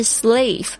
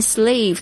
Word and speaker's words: slave: 再来是 slave， S-L-A-V-E，念 slave 的时候呢，那个 A 的发音要特别的加强一下， A A 的这个微笑的声音， slave，不是 slav，也不是 slave， OK，是奴隶。